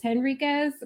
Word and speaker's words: Henriquez: So my name is Kelly Gretsch, and my family actually says Henriquez, my husband's family. So I'm just Kelly Gretsch Henriquez. Sorry, I know it's supposed to be Kelly, however you pronounce the Polish one. And it's not Enriquez.